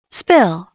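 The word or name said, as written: spill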